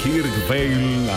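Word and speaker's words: Kirk Whalum: Desde Dallas, en Texas, Kirk Balun, así podemos decirlo en español, Kirk Vale, así se pronuncia en su idioma.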